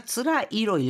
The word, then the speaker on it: 辛い: つらい로 읽어주고 이거를요. 辛い라고 읽었을 경우에는 맵다라는 뜻이 됩니다.